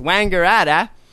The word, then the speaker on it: Wangaratta: No, I'm, that's how you have to pronounce these things. Wangaratta, which was 55 kilometers away, but was not cleared to travel over the speed limit or with lights and sirens.